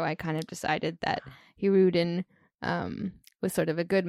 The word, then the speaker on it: Hirudin: I didn't know how to pronounce it either, and it's pronounced Hirudin.